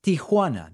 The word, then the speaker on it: Tijuana: where are you getting some of these additional syllables? It doesn't make any sense. Tia, like the word for aunt. Um, it's Tijuana. That's it, guys.